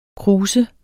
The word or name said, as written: krus